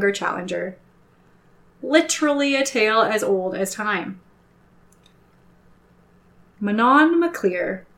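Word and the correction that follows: Manannán mac Lir: I said that wrong. Mananon MacLear. See what I mean with these Irish pronunciations? – Ooh, missed an entire syllable in there. – Okay. Rewind. Mananon MacLear, the sea god, is the king in question this time.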